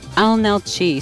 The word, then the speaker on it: Leenalchi: But Analchi, spelled L-E-E-N-A-L-C-H-I. The first L is silent.